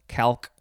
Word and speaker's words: calque: And then this is just from the Turkish word CalPac, K-A-L-P-A-K. So maybe we'll find a picture of this CalPac. Next is the last word. It is just pronounced calque. It is spelled C-A-L-Q-U-E, calque.